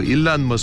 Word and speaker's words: Elon: 이렇게 발음하시고 엘론은 엘론 머스크, 일란 머스크 많이 들어보시죠? 사사의 이름입니다. 일란. E-L-O-N. 일란.